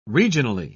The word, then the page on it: regionally: British English